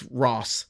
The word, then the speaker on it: Rohs: Well, it's spelled R-O-H-S. And, oh. – But it's Ross is what it is, the Ross Opera House.